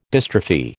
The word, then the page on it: dystrophy: American English